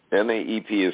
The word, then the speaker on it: NAEP: NAEP is commonly known with a single syllable as NAEP.